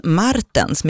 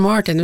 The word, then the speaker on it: Martens: Glenn Martens med betoning på R-et. Vem är det? – Ja, det är inte Glenn Martin utan han, Glenn Martens.